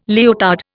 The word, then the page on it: leotard: Indian